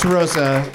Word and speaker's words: Rosa: How do you like to pronounce Rosa? – Rosa.